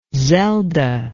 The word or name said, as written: zelda